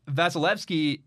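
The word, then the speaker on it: Vasilevskiy: It's like Vaseline, but Evsky. Vasilevsky.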